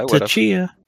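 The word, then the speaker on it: Tchia: So right now, it looks like we're looking at Hotline Miami 2, Tachia. Like, is that how you pronounce it? – It's a Tachia.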